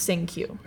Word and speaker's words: Cinque: Sink You. It's spelled C-I-N-Q-U-E. – C-I-N-Q-U-E. – But it's pronounced Sink You.